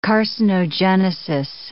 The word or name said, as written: carcinogenesis